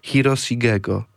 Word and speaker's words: Hiroshigego: czyli Hiroshigego, tak należy to wymawiać.